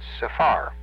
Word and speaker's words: sephar: The next one is, the accent is on the last syllable. It's sephar.